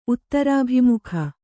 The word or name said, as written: ಉತ್ತರಾಭಿಮುಖ